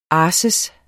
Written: arsis

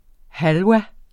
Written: halva

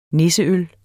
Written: nisseøl

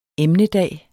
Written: emnedag

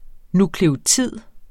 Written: nukleotid

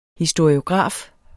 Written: historiograf

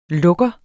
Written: lukker

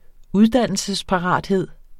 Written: uddannelsesparathed

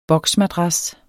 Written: boksmadras

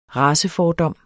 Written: racefordom

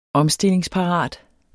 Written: omstillingsparat

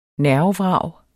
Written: nervevrag

